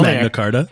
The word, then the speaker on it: Magna Carta: Did you say magnet with a T, Carta? – No, but that's fine. – Magna Carta. He said it correctly.